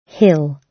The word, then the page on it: hill: British English